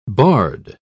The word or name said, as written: barred